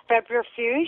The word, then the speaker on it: febrifuge: It functions as a febrifuge, is that how you say it? – Sounds okay.